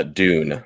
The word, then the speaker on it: Dune: Dune. I'll say it in flat American accent. – I don't know. This is like, but every time, whenever I say it, they're like, what? But anyway, so the Dune RPG, we're talking to Andrew Peregrine about that next week.